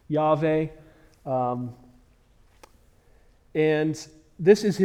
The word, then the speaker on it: Yahweh: Yahweh, I believe, is a close pronunciation of the tetragrammaton of the Hebrew four-letter words. We don't have vowels, or they don't have vowels in Hebrew. Some people believe it's pronounced Jehovah, or that's probably traditionally what you refer to it as in the King James Bible.